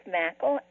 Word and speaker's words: Mackle: It is Mackle, and you can kind of think of it as rhyming with cackle.